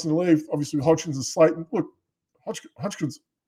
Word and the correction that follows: Hodgins: he's been fantastic. Hodgins. I can't even say his name.